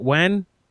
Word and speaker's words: Nguyen: N-G-U-Y-E-N? Nguyen? – Mr. N-G-U-Y-E-N. – N-G-U-Y-E-N. – Yeah, how do you say that? When? – When. – Yeah. – N. – Yeah. – G-U-Y-E-N. – N-G-U-Y-E-N.